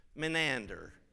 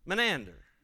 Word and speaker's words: Menander: Let's all say that together. Menander. Kind of rhymes with banana. Menander.